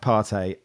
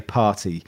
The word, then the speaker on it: Partey: I do think, even though it's pronounced Partey, I do really think anyone of a certain age should say party, because it just sounds like Martin Tyler is trying to sound down with the kids by saying Partey.